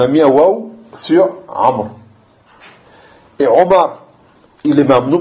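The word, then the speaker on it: Amr (عمرو): Seulement, ce waou, on ne le prononce pas. Tu dis pas Amrou.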